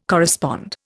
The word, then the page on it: correspond: Indian